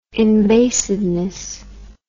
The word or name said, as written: invasiveness